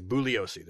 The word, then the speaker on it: Bugliosi: It's like it's Bugliosi. The G is silent.